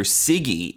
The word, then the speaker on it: Siggy: And we find out that she met What's-Her-Face through Siggy, and we get some Siggy flashbacks. – Soggy flicker. – She said rhymes with foggy.